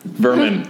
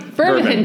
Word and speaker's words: vermin: How do you say it? – Vermum. – Vernum. – Vernum. Vermin. Vermin. Vermin. vermin. You get overrun by vermin.